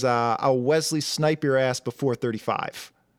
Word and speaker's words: Wesley: Wesley. There's no T.